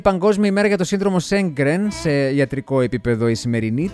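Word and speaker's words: Sjögren: Το σύνδρομο Σέγκρεν πήρε το όνομά του από τον Σουηδό οφθαλμίατρο Χένρικ Σέγκρεν. Στα σουηδικά προφέρεται Γέγκρεν, ο οποίος το περιέγραψε για πρώτη φορά το 1933 το σύνδρομο αυτό.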